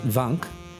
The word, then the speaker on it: Wank: Germany, there's a place called, I don't know how, well, okay, the Germans would pronounce it Wank, but it's spelled W-A-N-K, right? – Wank.